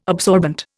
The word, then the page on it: absorbent: Indian